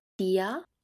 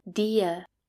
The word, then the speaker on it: dir: We saw dear as in wie geht's dir, dear, so we can either pronounce that dia with a or "dir." both is possible.